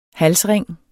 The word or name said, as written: HALSRING